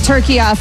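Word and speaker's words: Türkiye: I will be saying it turkey-a from now on. – And my entire lifetime, we have been incorrectly pronouncing turkey.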